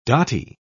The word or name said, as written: dotty